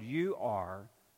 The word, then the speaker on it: Ur: It's spelled U R, Ur.